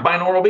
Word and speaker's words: binaural: And what are, I don't even know how to pronounce it, binaural beats? – Yeah, binaural beats. You got okay. It, 100%. Yeah, okay. Just like it sounds.